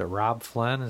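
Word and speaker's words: Robb Flynn: I've been trying to figure out the, is it Rob Flynn? Is that how you say his name? – Yeah.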